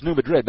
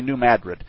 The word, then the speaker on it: New Madrid: The New Madrid is the name of, you'll see it pronounced as New Madrid, but as a Missourian, I can tell you it's really New Madrid.